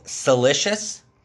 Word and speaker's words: Silicious: And then another dope podcast out there is called Silicious. Spelled like delicious, but with a C instead of a D on Spotify.